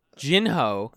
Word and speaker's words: Jinho: That's the way you pronounce his name in Korean. Jinho